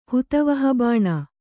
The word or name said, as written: ಹುತವಹಬಾಣ